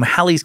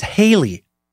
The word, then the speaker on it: Halley: No, I've always thought it was Haley. Like hail. Nope, Halley.